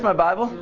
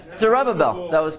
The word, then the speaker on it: Zerubbabel: How do you say that? Zerubbabel. My Bible? You're German. He's German saying, Zer's my Bible. That's good. All right. Zerubbabel. You were close.